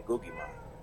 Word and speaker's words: Guggimon: Real quick, how do you how do you pronounce that figure? Is it is it Guggimon or? I say Guggimon.